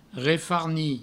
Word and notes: refarnir: refarni

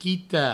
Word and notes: quitar: provençal; quita

